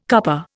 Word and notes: cuppa: Indian

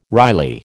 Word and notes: Riley: American English

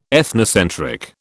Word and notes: ethnocentric: British English

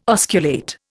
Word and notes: osculate: Indian

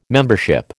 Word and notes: membership: American English

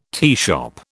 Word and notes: teashop: British English

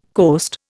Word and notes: coast: Indian

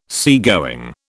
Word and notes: seagoing: British English

